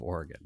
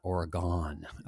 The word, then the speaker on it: Oregon: I just recently learned the pronunciation of Oregon, and it's not Oregon uh, out here in Minnesota, where we, we, give the long O's to everything.